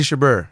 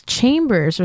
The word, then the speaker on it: Chabert: Lacey Chabert. – It's Chambers or something like that. – It's not Chambers, man.